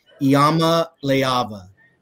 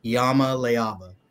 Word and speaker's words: Iamaleava: So get your pronunciation guides out. It's Nico Iamaleava. Iamaleava.